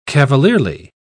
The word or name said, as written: cavalierly